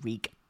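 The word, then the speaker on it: Rhug: As in R-E-E. – It's R-H-U-G, but you pronounce it Rieg.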